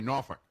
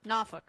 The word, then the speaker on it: Norfolk: Yeah, and you like to pronounce it correctly, Norfolk. – Norfolk.